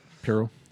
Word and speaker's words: Pirro: Anilo Pirro was down here watching us. Did I just butcher his last name? – Nope, Pirro.